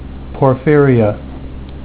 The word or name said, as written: porphyria